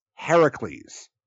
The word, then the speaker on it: Heracles: Zeus would have called Hercules Heracles, which is how his fucking name is in Greece. Hercules is the Roman equivalent of Heracles.